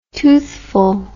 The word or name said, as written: toothful